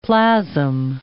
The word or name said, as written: plasm